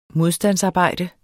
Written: modstandsarbejde

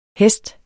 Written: hest

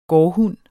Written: gårdhund

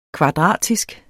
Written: kvadratisk

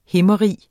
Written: himmerige

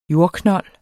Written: jordknold

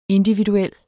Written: individuel

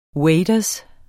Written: waders

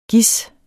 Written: gis

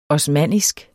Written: osmannisk

